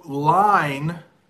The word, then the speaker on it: line: So line has one syllable, line. Lion has two syllables. So I can draw a line. There is a lion in the savannah. Hope that works. Line, lion. Line, lion.